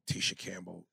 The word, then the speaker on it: Tisha Campbell: Tisha Campbell.